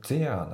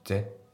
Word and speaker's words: there: で、あとはこの there なんだけど、これはまあ、 there なんて発音されてないですよね。ぜです。ほぼぜしか聞こえてないです。